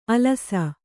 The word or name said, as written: ಅಲಸ